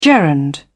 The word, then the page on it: gerund: British English